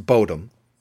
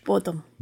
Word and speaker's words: Bodom: But consensus is that it's pronounced Bodum in Finnish. – Bodum.